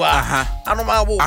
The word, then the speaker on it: Anomabo: Anomabo. – Anomabo. – No, no, anomabo. – Ah, na say anomabo. Ose eh anomabo. You pronounce it anomabo.